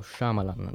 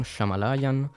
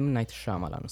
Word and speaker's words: Shyamalan: Mi raccomando, Shyamalan, non Shamalayan, non Shamanzin, Shyamalan. È facile, dai, è anche molto, molto figo da pronunciare. M. Night Shyamalan, suona bene.